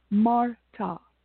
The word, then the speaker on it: Marta: But the sounds that I'm talking about is the sound of ah, like in my name, Marta, right? My name is spelled M-A-R-T-A, but it sounds M-A-R-T-A. So it's ah, so ah belongs to Venus.